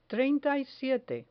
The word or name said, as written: treinta y siete